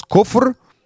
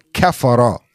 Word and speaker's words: kufr: Because the word in Arabic is kaf, the letter kaf, the letter fa, the letter ra. And it's a three-letter word that could be pronounced as kufr, which is disbelief, or kafara, the same exact spelling, but it's a different pronunciation. It's kafara, meaning someone who disbelieved, and kufr means disbelief itself.